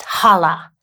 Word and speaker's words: kalah: And in that language, the word that he used is pronounced hala, K-A-L-A-H, which means to be complete, to finish, to accomplish, to put to an end, to make perfect.